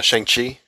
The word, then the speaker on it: Shang-Chi: Is that how you say it? Chi or Kai? – No, Shang-Chi.